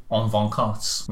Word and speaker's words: en vacances: Now, I was going to say that it's very much the same way as the Le Francais would say en vacances. No, I butchered that word. I absolutely butchered it. En vacances. Vacances? – Sounds right.